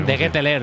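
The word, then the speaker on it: De Ketelaere: De Ketelare, lo había dicho bien Iturralde.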